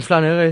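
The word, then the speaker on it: flâneur: Escolta, ens pots explicar què és un flanen? No sé si ho pronuncio flaner. – Bé. Flaner? Sí.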